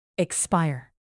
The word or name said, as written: expire